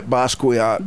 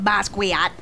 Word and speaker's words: Basquiat: Say it right, Basquiat. – Basquiat.